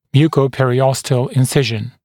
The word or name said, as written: mucoperiosteal incision